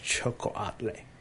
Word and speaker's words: xocoatl: It's spelled X O C O A T L, pronounced Chocoatle.